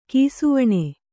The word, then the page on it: ಕಿಸುವಣ್ಣ: kisuvaṇṇa